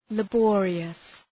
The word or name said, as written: laborious